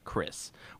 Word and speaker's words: Xris: You have the characters Chris Cyborg, who's actually spelled Chris with X-R-I-S. Formerly a federal agent, Chris, pronounced Chris, was brutally wounded when an investigation into a criminal organization, the Hung, turned out to be an ambush.